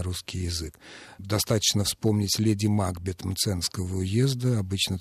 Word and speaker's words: Макбет: Действительно, если воспринимать его фамилию как шотландскую, вот с этой характерной приставкой «мак», то тогда ударение должно быть на втором слоге «Макбет».